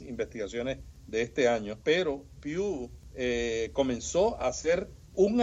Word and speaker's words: Pew: ¿Cómo se pronuncia esto? ¿Pew Research? – Pew Research. – Pew.